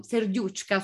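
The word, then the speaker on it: Serduchka: Il risultato è eh, Vierka Serucca, eh, no, Serdiucca, scusate, Serducca. – Eh, o Verka Serducca, dipende dalla pronuncia se russa o ucraina.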